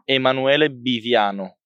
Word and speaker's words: Emanuele Biviano: Emanuele Biviano.